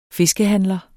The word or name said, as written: fiskehandler